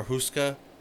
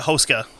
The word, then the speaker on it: Hoska: Everything I've heard is called it Hoska. – Hoska. – Yeah. – That's what it was. – Because apparently with Czech, with most words you put the emphasis on the first syllable of the word.